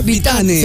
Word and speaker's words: Vitane: Es con E, V, ¿eh? Vitane. Se escribe Vitane. Vitane. Se pronuncia Vitan.